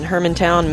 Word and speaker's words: Hermantown: Did I pronounce that right? – Yes, you did. – Thank you. Theology Uncapped in Hermantown, Minnesota.